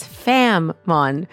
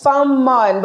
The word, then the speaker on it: Fanm: It's Fam-Mon, but it's a little heavy on the tongue. So I think you pronounce the M pretty heavily, which is nice.